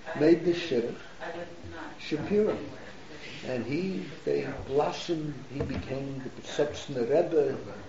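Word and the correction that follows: Piaseczner: And am I pronouncing it right, Yom? – Piazetzner. – How do you pronounce it? – Piazetzner. – Piazetzner, Rebbe.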